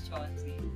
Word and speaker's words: Chawanzi: Well, you could say it that way, but that's not how initially it's pronounced. But like, I'm tired of telling people it's pronounced. So I'll call it Chawanzi.